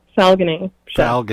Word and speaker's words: Falguni Sheth: Falguni Sheth, but yes, that's fine.